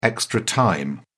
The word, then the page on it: extra time: British English